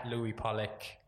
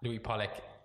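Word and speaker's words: Louis Pollick: All my social is at Louis Pollock. Louis Pollock, LOUISPOLLICK.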